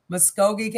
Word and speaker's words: Muskogee: Muskogee County.